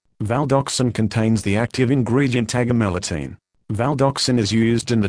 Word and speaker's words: agomelatine: Active ingredient, agomelatine, pronounced agomelatine. This leaflet provides important information about using Valdoxan.